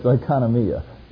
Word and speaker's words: oikonomia: Some people say it, they put the accent on the wrong syllable and it comes out kind of funny, like oink oink. But it's oikonomia.